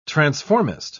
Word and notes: transformist: British English

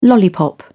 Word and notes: lollipop: British English

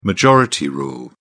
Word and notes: majority rule: British English

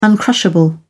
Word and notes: uncrushable: British English

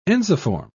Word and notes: ensiform: British English

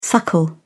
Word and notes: suckle: British English

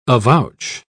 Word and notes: avouch: British English

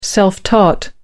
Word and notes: self-taught: British English

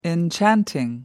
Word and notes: enchanting: American English

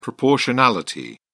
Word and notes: proportionality: British English

